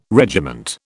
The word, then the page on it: regiment: British English